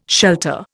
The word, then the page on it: shelter: Indian